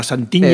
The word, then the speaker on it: Santiña: Santiña,